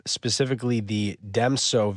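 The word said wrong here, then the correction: Djem So: variant. Is that how you pronounce it? – I believe you. – Let's try it. – Be Gemso. – Gemso? – Like Jaren, Den Jaren. Gemso.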